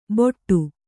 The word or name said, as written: ಬೊಟ್ಟು